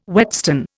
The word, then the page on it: whetstone: Indian